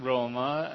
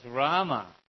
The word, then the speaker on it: Rama: Yeah, but people would say, hey, it's not Roma, it's Rama.